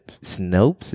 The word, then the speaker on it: Snopes: Despite these recurring assertions, the popular fact-checking website Snopes, is that how you say it? S-N-O-P-E-S? – Yeah, I think that sounds right.